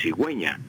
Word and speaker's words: cigüeña: Entonces, para que quede cigüeña y suene la U, se le colocan los dos puntos a la U y entonces queda cigüeña para que se pueda pronunciar correctamente, cigüeña.